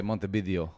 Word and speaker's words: Montevideo: So Spanish speakers would pronounce the V as a B. – Oh. – So they say Montevideo.